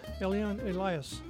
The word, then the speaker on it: Eliane Elias: Eliane Elias is the way I pronounce it, and she has a beautiful accent.